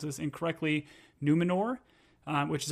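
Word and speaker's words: Númenor: Yeah, is that in the Numenor.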